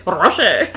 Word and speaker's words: Rocher: I like a Ferrero Rocher. – Rocher. – Oh my God. – You better say it right.